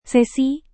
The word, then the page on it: sesi: Indonesian